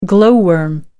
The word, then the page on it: glow worm: American English